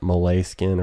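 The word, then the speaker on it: Moleskine: I've got my pad, or not my pad, it's kind of like a moleskin, although I think you're supposed to pronounce it Malay skin or whatever.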